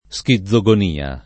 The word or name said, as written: schizogonia